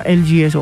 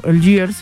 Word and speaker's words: Algiers: Vamos a escuchar una agrupación gringa, Algiers, se pronuncia Algiers o Algiers, pero Algiers.